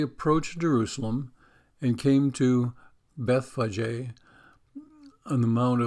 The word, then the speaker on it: Bethphage: Because it's not really pronounced Bethpage. It's the way I've always pronounced it, the way I've always heard it. But it's pronounced Bethphage or Bethphage. And that makes sense because there's that PH in there, which I don't know why that sounds like an F anyways.